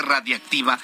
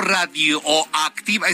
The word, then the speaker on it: radiactiva: Se volvieron a robar una fuente radiactiva. Radiactiva, así se pronuncia, radiactiva. No radioactiva, esa es una estación de radio que ya no existe.